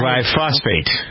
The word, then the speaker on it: glyphosate: Glyphosate.